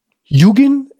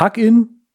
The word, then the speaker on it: Hugin: Ähm, wer dann mehr machen möchte, äh, dem kann man das Freeware-App Yugin empfehlen oder Hugin, je nachdem, wie, wie Leute das aussprechen wollen.